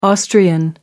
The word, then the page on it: austrian: American English